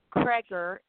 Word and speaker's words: Cregger: Cregger is C R E G G E R.